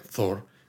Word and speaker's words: Thor: O sea, con lo bien que se te da a ti el inglés y esto, de verdad, ¿no eres capaz de pronunciar Thor con una H entre medias? – No, es Thor y Tora.